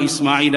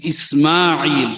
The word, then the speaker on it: Ismail: And it's not Ibrahim, how we generally say it and spell it also. It is Ibrahim, it is Ismail, not Ibrahim and Ismail. So we need to correctly pronounce these names when we refer to anyone by that name, call them by the correct name, Ibrahim, Ismail, properly as it's supposed to be called in the Arabic language, to the father of compassion.